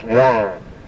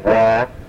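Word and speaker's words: wą: And it's wan. And instead of saying wah, push the hay through your nose.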